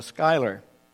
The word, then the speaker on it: Schuyler: but it's called a Schuyler. S-C-H-U-Y-L-E-R, I think it's pronounced, a Schuyler Bible, because this Schuyler Bible, which is published in the Netherlands, sidelines the chapter and verse divisions.